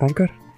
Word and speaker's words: Anchor: que se escribe, bueno, se escribe Anchor, pero es Anchor.